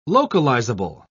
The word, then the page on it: localizable: British English